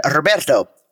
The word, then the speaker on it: Roberto: And I said, Roberto. R-O-B-E-R-T-O.